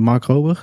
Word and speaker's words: Mark Rober: Mark Rober, zeg ik dat goed? – Ja.